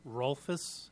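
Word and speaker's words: Rolfes: Mark Rolfus, and my business address is 215 South Cascade Street, Fergus Falls, Minnesota.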